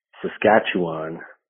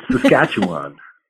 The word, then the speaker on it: Saskatchewan: Just in response to Allison's Instagram post, I pronounce it Saskatchewan. I could be wrong. I do live in Toronto, which is pretty far from there. But now that I say it out loud, it sounds kind of weird. Saskatchewan. That's how I do it.